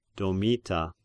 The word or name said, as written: Domita